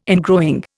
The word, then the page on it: ingrowing: Indian